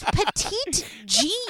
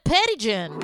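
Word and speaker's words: Petit Jean: But I was like, it's Petite Jean. It's literally the word petite and Jean, and they keep calling it Pettigen.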